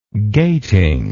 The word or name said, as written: gating